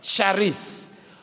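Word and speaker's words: charis (χάρις): So, this word, when it was used by the Greeks, that word grace is the word charis, spelled K H A R E C E. Some people spell it C H A R I S, charis, or charis. That's how it's pronounced.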